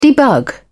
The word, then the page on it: debug: British English